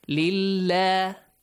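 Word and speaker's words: Lillah (لله): Now that you have Kasra before the word Allah, you pronounce the word Allah with an A sound. Lillah.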